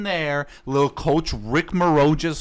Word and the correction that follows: Majerus: however the hell you pronounce his name. – Majerus.